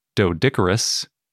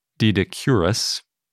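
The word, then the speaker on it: Doedicurus: There is a later glyptodont named Dodicurus, also Didicurus, depending on how you want to pronounce it, which was in Argentina and Uruguay.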